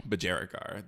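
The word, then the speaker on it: budgerigar: Bajarigard? – Bajarigar. They're Australian birds, so it makes sense. – Say it in an Australian accent. – Bajarigar. – Guard. – That's how you pronounce it correctly.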